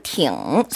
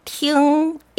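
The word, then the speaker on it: 팅 (听): 어, 3번은 팅, 삼성. – 네. – 4번은 팅, 일성.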